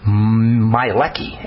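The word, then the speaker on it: Miehlke: Williams, and K. Mielecki, M-I-E-H-L-K-E, I think you say his name.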